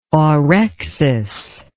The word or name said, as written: orexis